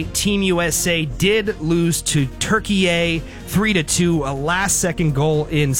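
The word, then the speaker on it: Türkiye: Yeah, it's Turkey, but everybody's pronouncing it Turkey A. All the announcers, I think that's the proper pronunciation for the country, so I decided to go with that pronunciation.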